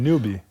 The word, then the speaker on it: newbie: Você viu que a gente é tudo Newbie. Newbie. Como é que fala? É. Newbie.